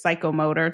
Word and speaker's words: psychomotor: The third one is psychometer. I usually pronounce it psychomotor to remember the spelling.